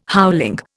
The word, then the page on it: howling: Indian